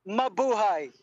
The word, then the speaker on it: Mabuhay: How do you say it? Mabuhay? – Mabuhay. Yes, correct.